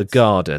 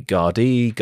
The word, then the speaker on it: Gardaí: Now, here come the Garda. The Garda are the Irish police. It's spelt G-A-R-D-A-I with a weird circumflex thing. Some people pronounce it Garda. Some people say Gardaí, Garda. I'm